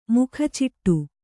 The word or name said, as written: ಮೊಕಚಿಟ್ಟು